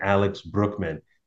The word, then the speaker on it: Brueckmann: Man, we are really excited to have none other than Alex Brueckmann.